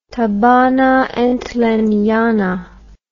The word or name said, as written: thabanantlenyana